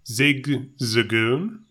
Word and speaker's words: Zigzagoon: So are you familiar with Zigazoon? – Zigzagoon? – Zigazoon. Is it Zigzagoon? – Isn't it? – You're absolutely right. I've been saying this wrong for, oh.